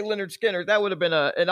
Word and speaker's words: Lynyrd Skynyrd: In this week's Florida being Florida segment, Florida man, wanted for stealing a trove of, Lynyrd Skynyrd. Is that how you say it? – No, that's Lynyrd Skynyrd.